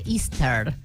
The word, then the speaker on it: Easter: Pero que no, dice Esther, no dice Esther, dice Easter.